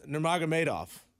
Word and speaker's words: Nurmagomedov: No, it's Nurmagomedov.